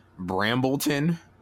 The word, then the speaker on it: Brambleton: Well, what we have here is track one, Brambleton. Did I pronounce that correctly? – Yeah. Yes.